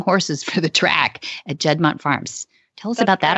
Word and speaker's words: Judmonte: I was amazed by this first internship that I read about that you were in your background, that you interned with the Judmonte Farms. How do you say that? Judmont Farms. There we go.